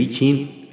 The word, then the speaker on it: Yijing: El Yi Yin eh, se escribe así, ¿verdad? Pero se pronuncia el I Ching,